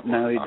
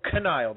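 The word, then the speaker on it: Knile: You do know Niall Davis. – Oh, Kenile Davis. Okay. – No. – we like the silent K's.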